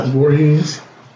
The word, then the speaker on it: Voorhees: Voorhees.